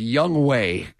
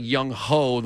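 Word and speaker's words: Younghoe: So I didn't know until yesterday that the way to pronounce this gentleman's name is Young Wei Koo. – Mm-hmm. – It kind of looked like Young Ho the way it was pronounced, but it's Young Wei.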